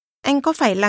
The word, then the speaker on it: anh: Anh. – Is pronounced with a mid-level tone. Say it again. – Anh.